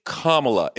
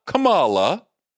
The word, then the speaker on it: Kamala: It's Kamala. It's not Kamala.